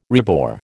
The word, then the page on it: rebore: American English